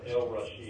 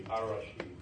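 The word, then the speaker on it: Ar-Rashid: Arabic letters, S being one of them, have an elision with an L before, so the L is not pronounced. The letter R is the same way. You wouldn't have El Rashid, it would be Ar Rashid.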